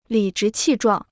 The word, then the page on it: 理直气壮: lǐ zhí qì zhuàng